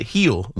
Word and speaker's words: Gil: But no, it's G-I-L, but they pronounce it heel, Luis Heel.